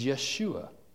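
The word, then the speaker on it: Yeshua (ישוע): The Hebrew pronunciation of Joshua is Yeshua.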